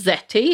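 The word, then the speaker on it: Zety: One of them that I found, which I think is pronounced Zetty, it's Z-E-T-Y, says Zetty lets you create a professional resume in less than 15 minutes.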